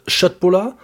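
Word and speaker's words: köttbullar: Wir hatten vegetarische Schöttbullar fertig.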